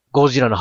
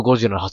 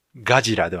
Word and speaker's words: ゴジラ: ゴジラの発音が日本のゴジラの発音だと。 – そうそうです。ガジラではなくて、ゴジラっていう発音ね。